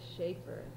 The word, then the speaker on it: Schaefer: Rebecca Ruth Schaefer. – And spell it, please. – The whole name, R-E-B-E-C-C-A, Ruth, R-U-T-H, Schaefer, S-C-H-A-E-F-E-R.